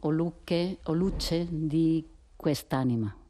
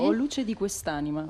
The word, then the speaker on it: O luce di quest'anima: Van a interpretar A tardai troppo, o luce di quest'anima. Más o menos, lo he dicho bien, más o menos. – Sì, sí, o luce di quest'anima.